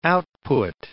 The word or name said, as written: output